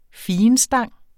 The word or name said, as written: figenstang